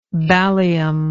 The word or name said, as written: ballium